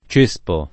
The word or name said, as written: cespo